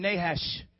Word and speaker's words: Nahash: Say it again, Nahash.